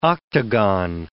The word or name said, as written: octagon